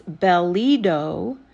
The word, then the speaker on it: Belito: My maiden name, which is Belito, is pronounced like a bell, and then the I sounds like an E.